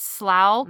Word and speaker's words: Slough: They pronounce it Slough, mm-hmm.